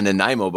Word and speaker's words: Nanaimo: Next item we got, man, a Nanaimo bar. – Okay, spell that. – N A N A I M O.